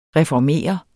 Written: reformere

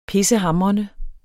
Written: pissehamrende

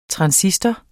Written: transistor

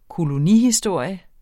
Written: kolonihistorie

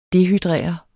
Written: dehydrere

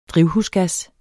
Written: drivhusgas

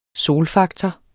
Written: solfaktor

